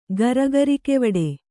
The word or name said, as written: ಗರಗರಿಕೆವಡೆ